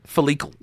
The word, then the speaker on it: follicle: I think you mispronounced that. Is it follicle? – That's it.